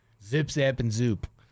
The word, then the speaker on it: Zoop: Zip, zap, and zoot? – Zoop. – Zoop. – Zoop. – It's spelled Z-double-O-P. – Yeah, it's like hoop with a Z.